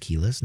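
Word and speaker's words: Kelis: Can you name the 2003 Keyless, I believe it's pronounced Keyless, not Kellis, song about bringing boys to the yard? – I have no idea about that one.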